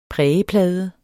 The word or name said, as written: prægeplade